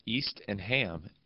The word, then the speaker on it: Eastham: It's East and Ham, EASTHAM.